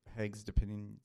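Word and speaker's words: Hugues de Payens: Around 1118 or 1118, a French knight named Hugues de Pénens, is that how you pronounce his name? – Hugues de Pénens. – There you go, thank you.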